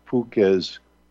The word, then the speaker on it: Fewkes: This book is called The Olosaka Cult of the Hopi Indians by Walter, but it's by J. Walter Fuques. It's spelled F-E-W-K-E-S.